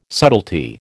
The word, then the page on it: subtlety: American English